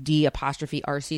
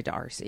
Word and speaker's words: D'Arcy: And it's one of those things that's spelled like D'Arcy. So I just say D'Arcy. – D'Arcy.